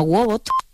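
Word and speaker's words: Woebot: que se llama Wobot, que se escribe con WWOEBOT, Wobot, y que es un asistente de salud mental en tu bolsillo.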